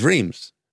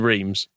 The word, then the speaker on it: Reims: I can definitely say, but this is Reims. It's easy. – So it's much easier to pronounce. – And Reims.